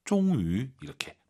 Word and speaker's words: 终于: 종위, 위 하지 마시고 와유 발음 입술 동그랗게 말아서 종위 이렇게 발음하시기 바랍니다. 고생 많으셨습니다.